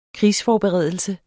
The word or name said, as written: krigsforberedelse